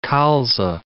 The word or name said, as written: colza